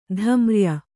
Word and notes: ಧಮ್ರ್ಯ: kannada

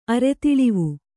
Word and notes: ಅರೆತಿಳಿವು: Kannada

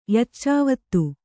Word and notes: ಯಚ್ಚಾವತ್ತು: Kannada

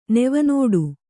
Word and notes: ನೆವನೋಡು: kannada

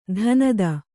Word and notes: ಧನದ: kannada

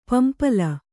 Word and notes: ಪಂಪಲ: pampala

